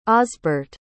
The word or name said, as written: osburt